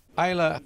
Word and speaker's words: Islay: Islay wird deswegen Islay ausgesprochen, auch wenn es Islay mit Y hinten geschrieben wird, weil es ursprünglich aus der gälischen Schreibweise kommt. Und in der gälischen Schreibweise wird es I mit dem Axon Graf drüber L-A geschrieben.